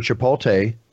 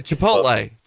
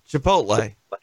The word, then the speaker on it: Chipotle: Then you have another situation where Chipotle, did I say that right, guys? I always get them wrong. – No, it's Chipotle. Chipotle.